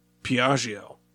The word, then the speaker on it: Piaggio: Now, this is one where we want to say, don't always be thrown off by the Italian double letters. In Piaggio, P-I-A-G-G-I-O, just treat it like one G. Piaggio.